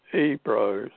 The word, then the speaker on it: Ebro: and that was originally on the river Ebro, that's spelled Ebro, which is very like Hebrew.